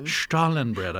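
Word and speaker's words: Stollen bread: But before we do any of that, is going to tell us about the history of, help me pronounce it, Stollenbread. – Stollenbread. – I said it correctly.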